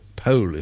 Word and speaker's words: Powell: particularly Charles Powell, who was her, or Pole, if you're very posh, is how you pronounce it, apparently,